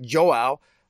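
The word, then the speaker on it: João: his name is Joao, uh, which I think is almost a play on Joel, the writer, but Joao. I, I actually had to I had I had Google pronounce me how to say his name.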